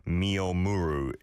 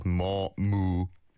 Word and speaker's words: 머무르: That's difficult for Western audiences, and in English it's M-E-O-M-U-R-U. 미오무루 is how I'm sure many people will pronounce it, but it's 머무르.